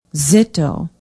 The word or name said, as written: zittau